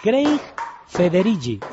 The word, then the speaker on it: Craig Federighi: Craig Federighi, y creo que se dice así, el nombre que es más inglés y el apellido que es más como italiano.